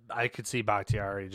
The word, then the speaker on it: Bakhtiari: Finally, they are getting back David Bakhtiari. – Yeah, that's huge. – Whatever the hell his name is. – You said it right. David Bakhtiari, yeah.